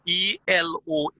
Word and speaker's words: Eloah: Number nine is Eloah. I hope I got that pronunciation right. It's Eloh. Eloh. And it means the one God.